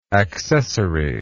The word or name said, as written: accessariness